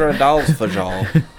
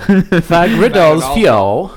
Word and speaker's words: Fagradalsfjall: Can you pronounce it? Fagradalsfjall. Fagradalsfjall. – Fagradalsfjall. – Fagradalsfjall. I think that's right on.